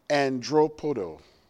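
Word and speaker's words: andropodo (ἀνδράποδον): It is pronounced, and I did it phonetically just so I could make sure I did it right. It is pronounced andropodo.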